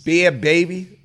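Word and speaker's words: Bhad Bhabie: Bad Baby. That's how you say it.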